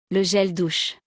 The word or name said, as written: Le gel douche